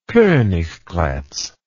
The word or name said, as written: Koniggratz